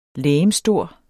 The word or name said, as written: legemsstor